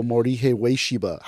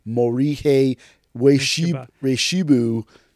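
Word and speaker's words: Morihei Ueshiba: How do you spell Morihe Ueshiba? How do you pronounce Morihe Ueshibu, as some people say?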